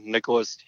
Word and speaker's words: Nicholas: My YouTube channel is just my name, Nicholas Heald.